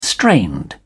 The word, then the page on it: strained: British English